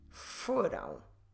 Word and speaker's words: foram: Vocês foram.